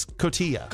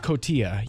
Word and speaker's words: Cotija: I hope I pronounce it right. – It's Cotija. – Cotija, yes.